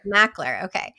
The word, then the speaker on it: Makler: Makler, okay.